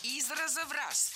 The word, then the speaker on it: из раза в раз: Из раза в раз. Здесь, в этом выражении, ударение поставили на предлог. Но это неверно. Должно быть из раза в раз. Предлог оставляем безударным.